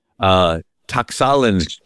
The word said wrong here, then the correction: Tlaxcalan: tribe that had been persecuted. And I know I'm not pronouncing that right. Tlaxcalan, T-L-A-X-C-A-L-A-N.